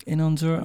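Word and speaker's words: Inon Zur: Um, it's on Fallout 4, and it's by Einon Zur. Is that how you pronounce his name? – Mm-hmm. Inon Zur, Einon. Inon, Einon Zur.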